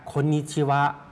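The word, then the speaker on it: คน นิ ชิ วะ (こんにちは): คนนิชิวะคนนิชิวะตัวคนออกเสียงยาวครับ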